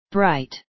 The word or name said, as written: bright